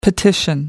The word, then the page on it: petition: American English